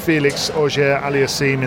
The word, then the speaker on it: Felix Auger-Aliassime: He won the tournament beating Felix Auger-Aliassime in the final.